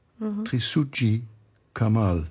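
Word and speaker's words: Trisutji Kamal: But today you brought music from an Indonesian composer, Trisuji Kamal. – Yes. – Do I pronounce it right? – Yes, that's correct.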